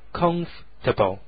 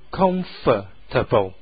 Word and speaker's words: comfortable: 在英语单词当中啊，一般前面发重音的接着下来的第二个那个音节啊，就会稍微弱一点，所以这个 comfortable 有时候你也可以听到别人把它读成 comfortable，那个第二个那个 for com 后面那个 for 的音节读得比较轻 comfortable。词典上面呢是 comfortable，两个读音都对 comfortable comfortable。